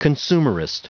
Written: consumerist

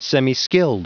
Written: semiskilled

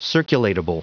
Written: circulatable

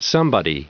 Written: somebody

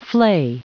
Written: flay

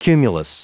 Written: cumulus

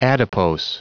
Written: adipose

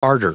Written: ardor

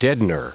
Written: deadener

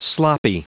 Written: sloppy

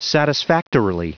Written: satisfactorily

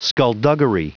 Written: skulduggery

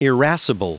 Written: irascible

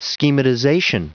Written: schematization

